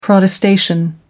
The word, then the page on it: protestation: American English